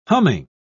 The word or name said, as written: humming